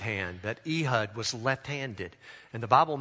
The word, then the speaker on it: Ehud: Ehud, that's how you say his name, Ehud.